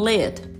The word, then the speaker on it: lit: Às vezes eles não pronunciam muito o T, né? O americano nativo ele tem, essa tendência de não pronunciar o T na, no final das palavras. Na verdade, é um stop T, né? Eles pronunciam, mas não sai o som. Seria mais ou menos assim, se for explicar. Então, lit é L-I-T.